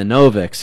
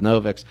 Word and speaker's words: Noviks: A couple buddies of mine, the Noviks, who rhyme with Slovak, but Noviks, they were in Syria at a school when the civil war broke out.